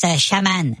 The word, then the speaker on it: chaman: Ça se prononce chaman.